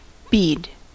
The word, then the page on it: bead: American English